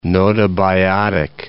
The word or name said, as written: gnotobiotic